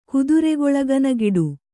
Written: ಕುದುರೆಗೊಳಗನಗಿಡು

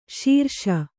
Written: ಸಿರಸ

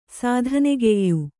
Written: ಸಾಧನೆಗೆಯ್ಯು

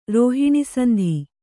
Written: ರೋಹಿಣಿಸಂಧಿ

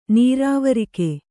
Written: ನೆರವೇರಿಕೆ

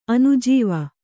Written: ಅನುಜೀವ

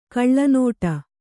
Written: ಗಿಳಿನುಡಿ

